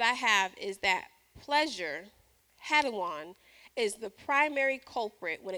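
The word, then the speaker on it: hedone: The Greek word is hedon, but it's spelled H-E-D-O-N, and it's where we get the English word hedonism.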